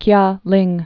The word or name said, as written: Kialing